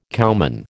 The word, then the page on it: cowman: American English